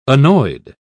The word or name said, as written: annoyed